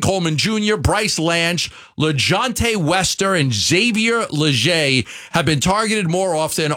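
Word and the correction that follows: Legette: Did I pronounce the name wrong? – Leggett. – Okay, Xavier. Close enough. I went with the French version.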